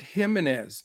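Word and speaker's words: Jiménez: So, well, you have to pronounce it Jimenez, no J, Jimenez, because I like Mr. Jimenez today too.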